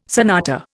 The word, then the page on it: sonata: Indian